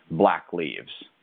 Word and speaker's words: BLVCKLEEVS: brand, Black Leaves, and it's spelled BLVCKLEEVS, right? – Yes.